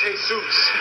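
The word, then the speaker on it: Jesús: Yeah, Indian Joe Roach and a Mexican fella named, Jesus Alvarez. – Hey, Bob, that's pronounced Jesus. – Well, he spelled it the same way Jesus spelled his name. – I know, but you don't say it that way.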